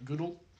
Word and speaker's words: Gull: It's spelled G-U-L-L, but it's pronounced like Goodle, I think is what it was.